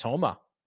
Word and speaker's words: Homa: He's worse than Max Homa. Why did I say it like that? Max Homa. Wow.